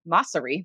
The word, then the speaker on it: Mosseri: Adam Mor- Moresi or Mosseri, that's actually how you say his name, is the head of Instagram.